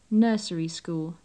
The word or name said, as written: nursery school